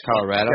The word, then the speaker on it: Colorado: Is it actually pronounced Colorado? – Yeah, Colorado. Yeah, Otto. Gotcha. Like an automobile, I guess, as Johnny Cash would say it.